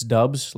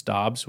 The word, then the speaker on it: Dobbs: It's just kind of a weird situation with that because half the people out there still think it's Dubs, like that's officially how you say his name, or it's probably not half anymore. It's like 10% haven't come to the realization that his name is not Dubs, it's Dobbs, which is, anyways.